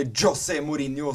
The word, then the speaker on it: Mourinho: Og nå skal vi snakke om José Mourinho, eller José Mourinho som han heter fordi han er fra Portugal, faktisk.